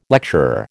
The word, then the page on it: lecturer: American English